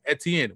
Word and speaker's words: Etienne: I'm glad you said that because everyone looks at me sideways because I pronounce it Etienne. When I'm pronouncing it the right way, the French way, because I'm from Louisiana, from New Orleans, where I knew more French than I knew anything.